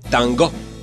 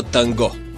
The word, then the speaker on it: танго: Танго! Всъщност, само във Франция и България се казва танго, с ударение на ото. В родината на тангото, Аржентина, правилният изговор е танго.